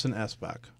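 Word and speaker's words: Espach: The Wedding People by Allison Esbeck. Is that right? – That is correct.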